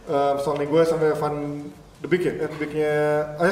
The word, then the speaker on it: Van de Beek: Kalau kalau Van Van Van de Beek ini sadar.